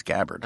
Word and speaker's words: Gabbard: I believe it's Gabbard. – Oh, okay. Like, rhymes with scabbard.